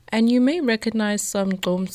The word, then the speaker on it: gqom: So it's spelled G-Q-O-M, pronounced GQOM.